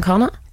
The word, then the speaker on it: Connacht: Ulster, Munster, Leinster and Connacht.